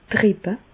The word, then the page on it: d' Rippe: Bas Rhin; Reichshoffen